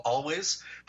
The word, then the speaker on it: Alvvays: A band that's slipped through the cracks, they're called Always, but they spell it A-L-V-V-A-Y-S, right?